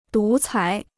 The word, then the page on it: 独裁: dú cái